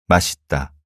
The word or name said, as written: 맛있다